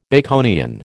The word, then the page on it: Baconian: American English